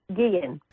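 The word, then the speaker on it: Guillén: Once and for all, I just heard that reporter say Guillen. I don't think that's correct. I don't want to disrespect their family by mispronouncing their name. How is it pronounced? – Guillen. – Guillen, thank you.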